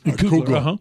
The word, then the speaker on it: Kugler: And how Sean, is it pronounced Kugler? Sean Kugler? – Kugler.